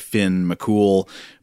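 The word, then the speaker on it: Fionn mac Cumhaill: notoriously tricky for English speakers like us. But I think that is also pronounced pretty much just McCool. So Finn McCool, uh, great name, by the way.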